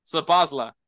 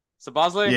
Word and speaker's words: Szoboszlai: uh, John, uh, Dominic Sapozla. Sapozla? – Yes. Szoboszlai, I think, is how you say it.